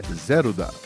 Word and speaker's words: Zelda: É, delas sim, porque a banda era formada apenas por mulheres, uma banda japonesa, então a pronúncia correta é Zelda.